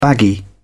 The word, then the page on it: baggy: British English